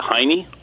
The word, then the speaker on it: Heine: And his name was Heinrich, R-I-S, I don't know how it's pronounced, but H-E-I-N-E, is that Heine? – Heine would be how he would say it in German.